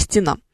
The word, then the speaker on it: стенам: Потому что люди обычно говорят, что у них картины висят по стенам, но на самом деле по стенам. И в стенах вот этой вот самой студии мы сейчас с вами будем разбираться что, правильно говорить, за стенами этой студии, по-другому как нибудь Да, вот ударение здесь только на последний слог.